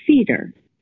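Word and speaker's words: Feder: We pronounce it Feeder as if there are two E's in the first part of the word.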